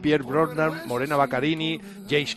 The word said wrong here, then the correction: Baccarin: ¿Cómo se dice? ¿Baccarin o Baccarin? – Baccarin. – No sé. Morena Baccarin es, ¿no? No Baccarini, sí. No, Baccarin, Baccarin, exactamente.